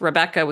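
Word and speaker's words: Rebekka: It's Rebecca with two K's, R-E-B-E-K-K-A, Mars, M-A-R-S, all one word, .com.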